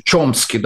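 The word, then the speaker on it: Chomsky: В Америке там Нам Хомский, он же Чомский, да, как по-американски произносится, или Тимоти Снайдер, историк.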